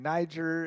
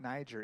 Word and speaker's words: Niger: John has helped organize short-term trips and missions in New York City, as well as in Poland and Niger and other places in the world. And John actually taught me, I've been saying it wrong for years. It's not Niger, it's Niger.